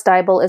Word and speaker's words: Deibel: And of course, Diebel is a lovely strange German name. So it's spelled D-E-I-B-E-L.